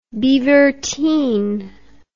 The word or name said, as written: beaverteen